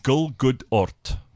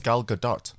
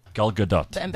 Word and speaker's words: Gal Gadot: Gulgudort says Jonathan Witt.